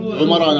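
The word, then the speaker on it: عمرو: طبعا هاي الواو هاي تكتب ولا تلفظ، هاي تمييزها عن عمر،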